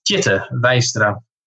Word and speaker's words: Tjitte Weistra: But it is pronounced Tjitte Weistra.